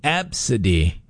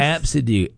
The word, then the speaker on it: Abcde: But it's pronounced Absidy. – Oh, my goodness. – Absidy. A, B, C, D, E, and it's pronounced Absidy. But that's how you spell it.